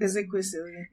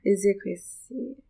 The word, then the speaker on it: Ezekwesili: But then following Obi Ezekwesili, I forgot how to, I don't know how to pronounce it. – Ezekwesili. Ezekwesili.